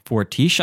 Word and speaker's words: Fortiche: Yeah, because Owen told me how to pronounce it. So there's a French studio that does all their animation. It's like Fortiche, I believe, is the name of the studio.